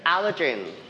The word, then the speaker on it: allergen: Allergen. – Allergen. – Allergen. – Allergen. – We stress the word on the first syllable.